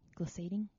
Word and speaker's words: glissading: There was a lot of, how do you say it? Glissading. – Yep, you nailed it.